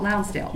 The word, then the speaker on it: Lonsdale: Mr. Lonsdale, please proceed. Am I saying your name right? – You are pretty good. It's Lonsdale, yes, Judge. – Oh, Lonsdale. – You got it.